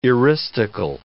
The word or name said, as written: eristical